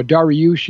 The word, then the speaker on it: Dariush: Now, Dariush is the Persian pronunciation of the word, of the name Darius that we read about in the Bible, like the Darius the Mede.